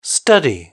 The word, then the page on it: study: British English